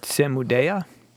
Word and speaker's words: Samudaya: And then the truth of the cause of suffering, which is, Samudaya? – I believe that is how it is pronounced.